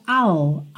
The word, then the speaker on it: I'll: Esse I will contraído fica I'll, I'll. Você só põe mesmo a linguinha lá no céu da boca.